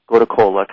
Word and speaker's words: gotu kola: It's a mild remedy This herb, by the way, is spelled G-O-T-U-K-O-L-A, and we want to distinguish that from another herb, kola nut, which is completely different. So gota kola,